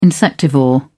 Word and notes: insectivore: British English

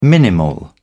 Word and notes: minimal: British English

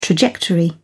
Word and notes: trajectory: British English

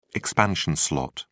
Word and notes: expansion slot: British English

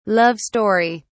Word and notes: love story: British English

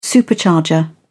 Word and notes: supercharger: British English

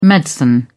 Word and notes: medicine: British English

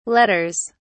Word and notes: letters: British English

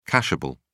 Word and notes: cashable: British English

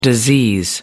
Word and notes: disease: American English

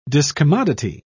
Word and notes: discommodity: British English

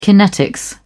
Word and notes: kinetics: British English

